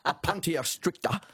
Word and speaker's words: Opuntia stricta: Well, we could go back to 1788 because the first colonial settlers brought prickly pear cactus or opoot, I knew I'd say that wrong, opuntia stricta.